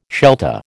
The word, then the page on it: Shelta: American English